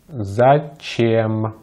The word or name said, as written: зачем